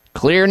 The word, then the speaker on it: Xlear: Clear nasal spray for people who have sinus problems, stuffed up noses, congestion. You want to breathe better. Clear is spelled X-L-E-A-R.